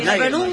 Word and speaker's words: Nagelsmann: Claro, es Nagelsmann.